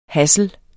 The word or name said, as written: hassel